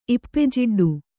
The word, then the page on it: ಇಪ್ಪೆಜಿಡ್ಡು: ippejiḍḍu